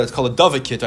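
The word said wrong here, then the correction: dovecote: It's a big, you know, how you pronounce it? – Dove coat. – Thank you. Dove coat.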